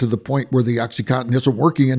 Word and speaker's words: OxyContin: And then when it became addictive, the OxyContin people said, am I saying that right? – OxyContin.